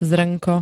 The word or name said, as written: zrnko